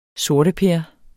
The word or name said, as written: sorteper